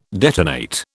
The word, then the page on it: detonate: British English